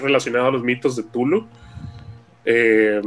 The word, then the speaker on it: Cthulhu: De hecho, creo que existe una carta donde él aclara la forma correcta de pronunciarlo. Que según él se pronuncia como Clulu, pero.